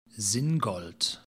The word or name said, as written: singold